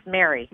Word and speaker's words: Mary: Yes, Mary. – Yes. Okay. – M-A-R-Y, Would you like to have a connection with Spirit? – Yes, I would.